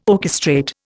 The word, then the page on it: orchestrate: Indian